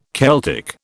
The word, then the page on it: Celtic: British English